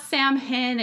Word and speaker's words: Samhain: It's actually spelled very differently from how it sounds in English, but it's spelled S-A-M-H-A-I-N. It's not Samhain. It's not Samhain.